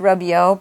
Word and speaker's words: Rubio: Juan Carlos Diaz, Gus Ainsworth, Mondo Chu, and me, Larry Rubio, pronounced Rubio by me, like a rubber ducky toy, my own surname.